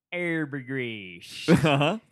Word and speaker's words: Arbogast: Because it's actually Milton Arbogast.